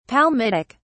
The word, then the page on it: palmitic: British English